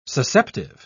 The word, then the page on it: susceptive: British English